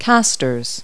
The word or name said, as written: castors